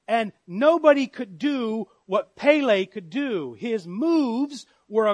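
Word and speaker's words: Pele (פלא): I pronounced this word as Pele. And it reminded me of the great Brazilian soccer player, Pele.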